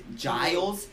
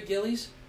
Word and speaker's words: Giles: And Giles, Harry Giles hasn't played yet. – Harry Gillies? – Yes, that would be the incorrect way to say it, but a fun way to say it.